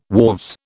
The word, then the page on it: wharves: British English